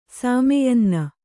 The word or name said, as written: ಸಾಮೆಯನ್ನ